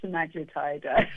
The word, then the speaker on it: semaglutide: You said it right, semaglutide. But it is a bit of a tongue twister.